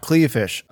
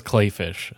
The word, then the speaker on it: Kleefisch: Not Kleefisch, apparently. – Even though it's spelled like it should be Kleefisch. Everyone says Kleefisch, so are we.